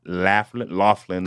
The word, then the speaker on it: Laughlin: Laughlin, Nevada.